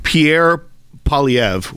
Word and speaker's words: Pierre Poilievre: but they are currently Pierre Poilievre, which is the best I'll do at my French pronunciation here.